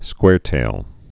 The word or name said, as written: squaretails